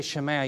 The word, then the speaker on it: Shema: And, well, I've never heard sabotage before, but I have to tell you that I've always rendered Shema, Shema. That's how I learned it in Hebrew. So if I say Shema, you'll understand that I'm not trying to be hoity-toity, but that's how I understand a proper pronunciation to be.